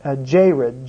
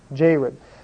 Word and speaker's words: Jared: Also in verse 2 of 1 Chronicles, you have the person named Jared, J-E-R-E-D, Jared. Well, in Genesis, it's spelled J-A-R-E-D. The E is changed to an A, but it's pronounced exactly the same way, Jared.